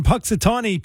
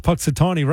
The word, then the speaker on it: Punxsutawney: Yeah, but you do realize I pronounced Punxsutawney right this time.